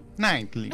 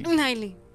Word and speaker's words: Knightley: Como que te traba un poquito antes de la N. Knightley. – Knightley.